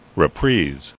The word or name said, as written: Reprise